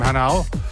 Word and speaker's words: Hanau: Warming up now with some nice deep house for the forthcoming DJ, who is none other than C-Rock, coming from Hanau.